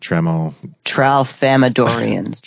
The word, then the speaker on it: Tralfamadorians: Tralfamadorians. – Tralfamadorians.